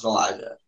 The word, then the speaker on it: Elisha: Like, spelled like the girl's name, Alicia, like E-L-I-S-H-A, but pronounced Elijah.